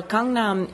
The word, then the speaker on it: Gangnam: Well, Gangnam is, which is how it's said in Korean, is an area of South Korea, of Seoul, in fact.